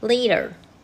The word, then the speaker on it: later: 所以很多人会把 later 变成 letter，很大的原因他不知道 later 其实从 late 这个字变过来的。那 late 它的结尾是 silent e，所以它变成 later 的时候呢，它一样这个 a 还是念长母音，它绝对不会变成 letter。